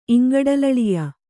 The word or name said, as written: ಇಂಗಡಲಳಿಯ